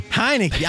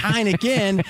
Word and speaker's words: Heinicke: How do you pronounce his name? – Heinicke. – Heinicke. I was thinking Heineken, and I'm like, it couldn't be Heineken.